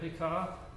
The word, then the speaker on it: Pekah: I'll just pronounce it, the English look at it, Pekah.